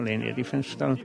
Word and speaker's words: Leni Riefenstahl: Dakle, ostanimo kod izgovora Leni Riefenstahl.